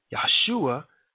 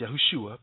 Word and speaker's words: Yahushua: Yahshua, okay, now it should be pronounced Yahushua, but Joshua and Moses, they represented this fourth generation.